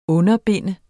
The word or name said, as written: underbinde